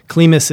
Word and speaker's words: CLIMAS: And Climas is spelled C-L-I-M-A-S or Climas.